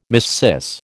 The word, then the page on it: missis: American English